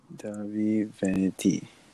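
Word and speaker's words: Dahvie Vanity: D-A-H-V-I-E, Vanity. – Davi Vanity.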